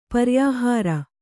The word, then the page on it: ಪರ್ಯಾಹಾರ: kannada